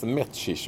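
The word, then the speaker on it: Mečíř: Men du, på tal om undanservar, kommer du ihåg en spelare som heter Miroslav Mesir, eller Metchir, hur uttals? – Ja, jag fick, tror man fick lära sig att det skulle uttalas Metchish också, det var en sån uttals.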